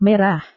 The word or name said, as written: merah